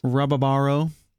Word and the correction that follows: Rabarbaro: Um, Rabarbaro.